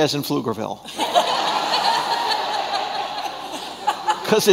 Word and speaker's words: Pflugerville: By the body of Christ, maybe I'm not talking about people here in Georgetown, but in Pflugerville. and some of the surrounding, is, is that how you say it? Yeah, this, the P is silent.